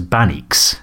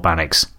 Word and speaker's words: Baaneex: This is Bannocks, I believe. It's spelled B-A-N-E-E-X. And it's Wolof, apparently, which is W-O-L-O-F.